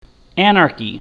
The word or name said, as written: anarchy